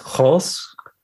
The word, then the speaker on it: Grolsch: It's Grolsch or something like that. It's a hard G.